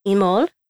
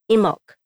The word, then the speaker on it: Imbolc: but it's also pronounced Imol and Imbolc, so without the B sound.